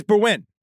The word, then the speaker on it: Berwyn: Well, no, I saw it's Welsh, so it's going to be a strange pronunciation. – It's Berwyn.